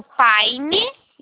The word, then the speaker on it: Pine: Eu preciso do Paine, tá? Ele se escreve PINE.